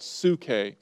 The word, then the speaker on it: psuche: It's called psuche. And I believe it's P-S-U-C-H-E. P-S-U-C-H-E. Psuche, sometimes translated soul or life.